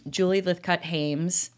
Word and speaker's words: Julie Lythcott-Haims: But if we don't, the book, again, is How to Raise an Adult by Julie Lythcott-Haims. Did I say that right? – You sure did.